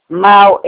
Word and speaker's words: Mahl: Mal, MAHL.